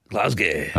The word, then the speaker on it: Glasgow: Is that how you say it? – Glasgow. – Okay. – That's how you have to say it, yeah.